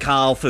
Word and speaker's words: calf: Oh, don't tell me you ignore the L in calf as well.